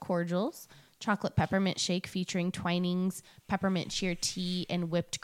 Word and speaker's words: Twinings: For beverages, they will have hot cocoa, chocolate peppermint shake featuring Twinings. Is it Twinings? – Twinings. – Okay, I knew I was saying that wrong.